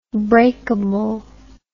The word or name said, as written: breakably